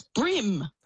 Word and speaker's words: bream: I've been saying Bream all my life. Is it not Bream? Is it Brim? – Oh, it's Brim. – Oh. – Brim, like on your hat.